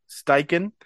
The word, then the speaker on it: Steichen: We have Shane, is it Steichen? Am I pronouncing that Steichen. – correct? Steichen. – No R.